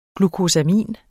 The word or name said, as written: glukosamin